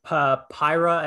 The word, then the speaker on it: Pyra: Yeah, and then we had Pyra and Mithra from Xenoblade series. I'm probably not pronouncing those names correctly. – No, you got those games perfect. Yeah, you got those names perfectly.